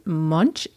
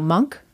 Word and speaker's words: Munch: The Scream by Edvard Munch. No, Monk.